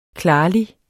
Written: klarlig

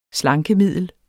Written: slankemiddel

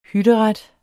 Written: hytteret

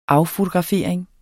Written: affotografering

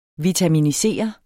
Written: vitaminisere

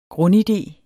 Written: GRUNDIDEEN

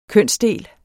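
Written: kønsdele

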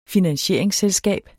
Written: finansieringsselskab

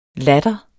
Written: latter

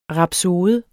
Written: RAPSODERNES

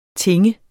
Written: tinge

